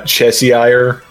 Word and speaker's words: Cheshire: It's pronounced Cheshire. – Cheshire. – Fuck off, like, both of you. – Like the Worcestershire sauce.